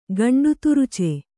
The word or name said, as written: ಗಂಡುತುರುಚೆ